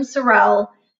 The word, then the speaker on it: Sorrelle: And I'm literally the only Kim Sorrelle on the face of the earth that is spelled the way it is.